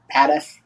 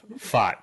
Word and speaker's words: Pfaadt: Padef. – Fart, yeah. – Fart, I call him Fart because it sounds like, but it's Fart, yeah. P-F-A-A-D-T.